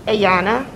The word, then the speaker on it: Ayanna: A-Y-A-N-N-A. – How do you say your first name? – Ayana. Yes. – Davis? – No, Davis is my daughter. I'm Carr. C-A-R-R.